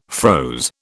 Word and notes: froze: British English

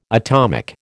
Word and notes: atomic: American English

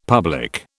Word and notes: public: British English

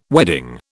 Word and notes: wedding: British English

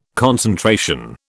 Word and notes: concentration: British English

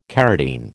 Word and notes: carotene: American English

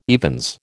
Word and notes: evens: American English

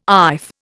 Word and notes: iff: Indian

